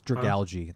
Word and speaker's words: Dragalge: It's Dragalge. It's like algae, I think. – Ah, Dragalge.